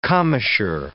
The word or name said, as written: commissure